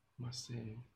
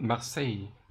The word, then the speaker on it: Marseille: Marseille. Oh, it's spelled in an English way, but Marseille. Is a city at the south coast of France.